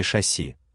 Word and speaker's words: шасси: И все из-за правой стойки шасси, с ударением на первый слог.